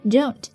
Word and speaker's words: don't: 来看一下发音 Don't, 词尾的 T, 省掉 Don't mess around.